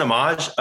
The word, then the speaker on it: Amash: Am I saying it right, Amash? – Justin Amash.